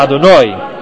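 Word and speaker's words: Adonai (אדני): So it's not Adoi and then Nai. It's Ado, and then the emphasis symbol is Noi. It's a stronger syllable. If you say it, Baruch Ata Adonai, it's the latter, the last syllable, that's the emphasized one.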